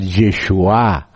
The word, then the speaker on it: Yeshua: Y uno era el aspecto sacerdotal, Yeshua ben Yosadak, o sea, Jesúa o Josué, que es otra pronunciación, pero la pronunciación más antigua es Yeshua, igual que Jesús.